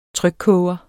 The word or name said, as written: trykkoger